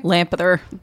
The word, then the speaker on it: Lampeter: Storyscape Journal, The Lamp Peter. – Lampeter. – The Lampeter Review.